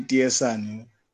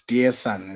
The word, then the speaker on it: Dearson: It's actually Dearson. It's Dearson, as in dear and daughter, opposite of dear daughter. So yeah, that's the correct pronunciation.